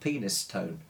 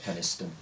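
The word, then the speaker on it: Penistone: It's called Penis Stone. – Yes, very good, yeah. – It's near Penis Stone Road, which I think is actually pronounced Peniston.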